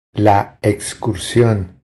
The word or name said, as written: La excursión